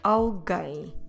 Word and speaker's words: alguém: The word alguém. Have you heard this word before? Alguém. Como se escreve alguém? A. L. G, U, E, com acento, M, alguém. Notice that the letter U is silent.